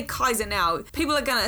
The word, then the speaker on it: Caesar: Like Caesar, I'm pronouncing wrong as well. It should be Kaiser because the Latin does not have soft C's. We have softened them, so it should be Cicero and Kaiser.